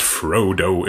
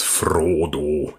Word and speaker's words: Frodo: it's not Frodo, it's Frodo.